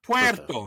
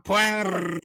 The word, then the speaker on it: Puerto: First of all, you got too many R's in the Puerto. – Too many R's. – Puerto, not Puerto. – Puerto.